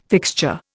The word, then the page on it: fixture: Indian